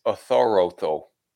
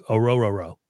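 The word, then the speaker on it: Orhorhoro: I think it's Othoroto. – I don't think so. – All right. Well, if, you know, we had a producer that would actually produce pronunciation on names, it would really help. – Well, I just looked up the pronunciation, and it's Rook Oro-Roro. – Oro-Roro? – I just want to hear you say that. Yeah, that's what I've got from the Clemson website.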